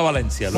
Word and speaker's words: València: Encantado de estar en el Media Center de la Marina Nord del Port de València.